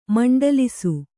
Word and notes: ಮಂಡಲಿಸು: maṇḍalisu

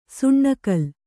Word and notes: ಸುಣ್ಣಕಲ್: suṇṇa kal

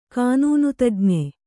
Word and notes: ಕಾನೂನುತಜ್ಞೆ: kānūnu tajñe

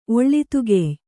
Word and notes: ಒಳ್ಳಿತುಗೆಯ್: Kannada

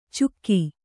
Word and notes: ಚುಕ್ಕಿ: kannada

